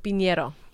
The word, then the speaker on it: Pinero: Um, Piñero.